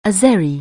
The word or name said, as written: Azeri